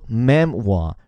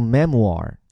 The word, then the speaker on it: memoir: 虽然在我们的免费晨读营里，我们的认证究竟是会对每天的微头条发音做更系统的讲述，但这个词我要单提一嘴，就它的发音，大家把它分两半，一半是前面三个字母 m e m，把它发作 mem，然后后面这个 o i r 发作哇，所以叫做 memoir。如果是美音呢，后面带个儿化音，叫做 memoir。